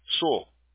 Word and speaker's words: Sewell: Se escribe Sewell, algo así. S-E-W-E-L-L. – Ah, sí, S-E-W-E-L-L. Su.